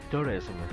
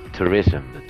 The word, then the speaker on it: Tourism: nos cuenta sus anécdotas relacionadas con el Dark Tourism. No sé si lo he dicho bien, buenas tardes. – Buenas tardes. Bueno, te falta un pelín de pronunciaciones. Tourism.